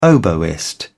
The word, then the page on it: oboist: British English